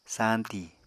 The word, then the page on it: sañti: Apatani